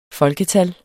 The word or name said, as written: FOLKETALLENE